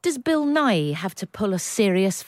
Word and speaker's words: Nighy: Bill Nighy!